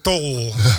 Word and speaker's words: Tol: En dat is niemand minder dan onze Cordol. – Je wel goed uitspreken, want in Edam woont de familie Dol. – Oké. Okay. – En wij zijn de familie Tol. – Oh, je bent de Tol.